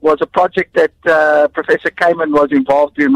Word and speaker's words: Kamen: Has got some insights into Dean, how do you pronounce it? Kamen. Dean Kamen.